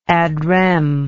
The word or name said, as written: ad rem